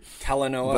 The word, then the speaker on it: Talanoa: Talanoa.